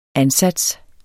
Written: ansats

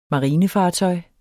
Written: marinefartøj

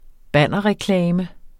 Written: bannerreklame

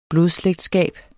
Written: blodslægtskab

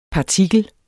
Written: partikel

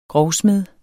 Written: grovsmed

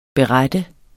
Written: BERETTER

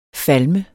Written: falme

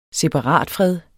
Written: separatfred

